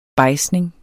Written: bejdsning

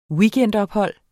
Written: weekendophold

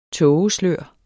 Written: tågeslør

